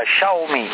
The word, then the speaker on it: Xiaomi: Ecco perché mi sono fatto un'idea su questi Xiaomi, che poi si pronuncia Xiaomi,